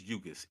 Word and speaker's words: Ugás: How do you say his first name? Your Danis? Your Dennis? It's Ugás. It's Ugás.